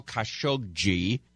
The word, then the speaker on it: Khashoggi: We're going to update you on this crazy story about the missing journalist Jamal Khashoggi. That's how you say his name, I found out.